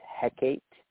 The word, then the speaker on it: Hecate: Now, the goddess that's most associated with tarot is Hecate. Now, sometimes that's pronounced Hecate. It's spelled Hecate, but there's also an Hekate spelling of it as well. It's all the same thing. You can say Hecate, you can say Hecate, goddess of magic, witchcraft, the night, moon, ghosts, necromancy.